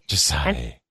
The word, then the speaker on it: Xocai: It's called Jasai. It's spelled X-O-C-A-I. – X-O-C-A-I. – It's pronounced Jasai. – Jasai.